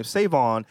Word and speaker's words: SaVon: Because when they see Devon, or when they see my name, Savon, they pronounce it Savon, Savin, all types of shit.